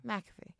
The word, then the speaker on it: McAfee: I feel like when you're with McAfee or when you're doing the is it McAfee? Is that how you pronounce it? Or McAfee? – McAfee.